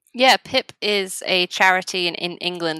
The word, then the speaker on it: PIP: And something else I was going to say as well is that you mentioned to me that you're working with, is it PIP? Is it pronounced PIP? – Yeah, PIP.